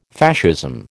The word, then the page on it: Fascism: American English